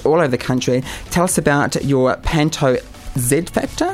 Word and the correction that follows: Oz Factor: is that how you say it? – It's Oz Factor. – Oh, Oz Factor. Yes.